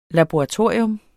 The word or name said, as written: LABORATORIE